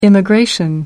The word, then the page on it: immigration: American English